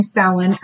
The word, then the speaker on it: Samhain: Okay, it looks like Samhain. S-A-M-H-A-I-N, but that's not how you pronounce it.